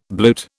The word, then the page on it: bluet: British English